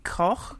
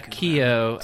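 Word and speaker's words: Keough: I'll never pronounce her name correctly, Riley Koch, perhaps? – Mm. Yeah, I always thought it was like Keo. – Yeah. Oh, maybe. – Yeah, Keo.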